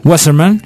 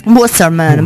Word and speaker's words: Wasserman: Tra l'altro è stato anche realizzato proprio un adattamento scenico esattamente nel 1971, pensate, da Dale Wasserman. Si pronuncia così? – Wasserman. – Wasserman, bravissimo, che appunto realizzò per Broadway proprio un adattamento scenico.